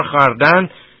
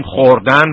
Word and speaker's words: خوردن: اینجا خردن که حالا ما در فارسی امروز بیشتر میگیم خوردن، ولی خردن تلفظ میشده در قدیم.